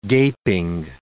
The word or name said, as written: gaping